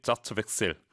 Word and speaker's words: Satzwechsel: 예, 제가 스펠링 정확히 알려드릴게요. SATZWECHSEL. 정확히 자츠백셀. 독일어로 읽습니다.